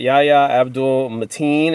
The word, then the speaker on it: Yahya Abdul-Mateen: Uh, and uh, I'm gonna butcher this guy's name, but Yaha Yahya Abdul Mateen is back as Black Manta, the the villain of the first movie.